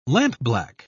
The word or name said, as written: lampblack